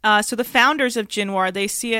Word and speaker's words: Jinwar: So I'm talking about the village of Jinwar, and that's spelled J-I-N-W-A-R.